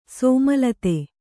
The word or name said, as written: ಸೋಮಲತೆ